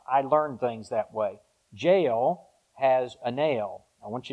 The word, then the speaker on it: Jael: Not J-A-I-L, J-A-E-L, but it's pronounced Jael.